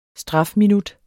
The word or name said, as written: strafminut